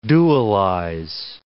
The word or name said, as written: dualize